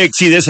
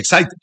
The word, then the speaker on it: Exide: Dentro se escribe Exide, se pronuncia Exide.